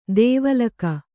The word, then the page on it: ದೇವಲೋಕ: dēva lōka